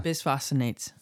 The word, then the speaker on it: Bisphosphonates: Bisphosphonates.